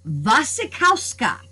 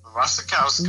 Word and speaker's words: Wasikowska: Let me see if I can get this right. Wasikowska is how it's pronounced. – Wasikowska. Nice. Wasikowska, yeah.